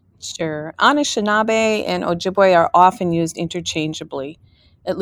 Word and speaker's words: Ojibwe: So I am Ojibwe, and that's how it's pronounced.